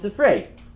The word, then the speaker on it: Sifrei: Not Sifri, that it's mine, because it's not mine any more than anyone else's. People often mispronounce it as Sifri.